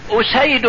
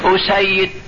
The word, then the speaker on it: أسيد: بمعنى يقول مثلا أسيد بن حضير، أسيد، أسيد، الفرق بينهم الهمزة والسين والياء والدال هي هي، لكن النطق جاء بتشديد الياء مصغر وجاء بتخفيفها مخفف.